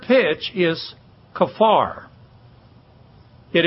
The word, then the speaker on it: kaphar: In the Old Testament, the word for atonement is kafar, K-A-P-H-A-R. We normally hear a lot of people talking about this is kampur or kampur, but the word is pronounced kafar, and it means something very clearly.